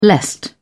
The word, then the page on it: lest: British English